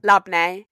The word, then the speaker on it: labneh: We've got labini. Is that how you say it? – Labne.